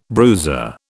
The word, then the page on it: bruiser: British English